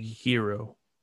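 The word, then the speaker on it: Herro: But the name that surprises me is their other young 21-year-old kid in Tyler Harrow, apparently being Hero. – Sorry. I pronounced the two R's instead of the, like, if there was another O there.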